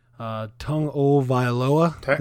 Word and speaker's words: Tagovailoa: Tagovailoa is how you actually pronounce it.